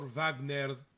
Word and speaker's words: Wagner: who is the son of Dr. Wagner Jr. Did I say that right? – Yep.